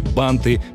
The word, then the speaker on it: банты: Если запомнить это слово и его историю, вопрос с ударением в слове «банты» просто отпадет. Никому ведь не придет в голову сказать «аксельбанты».